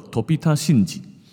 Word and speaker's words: 飛田新地: 飞田新地这个发音哦，叫做 Tobita 信吉，可以怎么记呢？下次去日本的时候，你就这样子记吧，叫做头比他心急，你的那个头比他的心还要急，叫做头比他心急，Tobita 信吉。